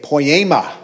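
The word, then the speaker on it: poiema (ποίημα): The word workmanship is the word pronounced poiema. Everyone say poiema.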